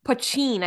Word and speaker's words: poteen: Poutine, I think it's pronounced. – He pronounces it poutine, but the captions spell it P-O-T-E-E-N.